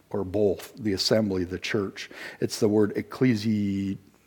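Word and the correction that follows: Ecclesia (ἐκκλησία): Yes, that word. I have a hard time pronouncing them. Beth? Ecclesia, there you go.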